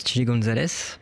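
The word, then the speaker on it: Chilly Gonzales: C'était donc la nouvelle sortie de Ziné, elle est sortie il y a 2 semaines, qui est en feat avec, alors comment ça se prononce, Chilly Gonzalez, Ouais, j'aurais dit pareil.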